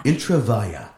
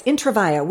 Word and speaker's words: Intravaia: I always wanted to know how to pronounce it. – Yeah. – Intravaya. – Yes, Intravaya, which is why his stage name is Mark Twang with a G, because nobody. – I wondered if that was one and the same. – Intravaia. Nobody can pronounce that.